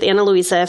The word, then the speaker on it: Ana Luisa: So if you guys are interested in checking out our partnership with Ana Luisa, first of all, it's spelled A-N-A and then Luisa is L-U-I-S-A.